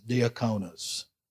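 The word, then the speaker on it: diakonos: I looked it up and how to pronounce it, and there's a lot, however many ways you see to spell it there, that's that many ways to announce it. But the one I like best is diaconos. So that's what we're going to go with.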